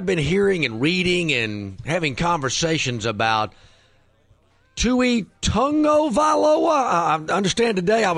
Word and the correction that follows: Tua: He blew the first name, though. It's Tua, not Tui.